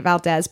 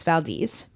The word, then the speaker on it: Valdez: The Exxon Valdez is a boat, right? – It is a boat. – And it got the oil from the port, the Valdez. I keep calling it Valdez, but it's Valdez.